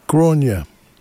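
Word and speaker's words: Gráinne: And we started the set off with a surprise, a brand new release from, I've looked up how to say this, Grainne. Grainne Duffy comes from Ireland, and her name is spelt G-R-A-I-N-N-E, bit like Siobhan, doesn't sound how you read it.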